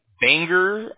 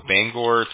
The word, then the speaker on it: Bangor: I have you in Bangor. I'm not sure if I'm saying that right. Bangor, Maine. Is that correct? – Yeah, that's where I'm at.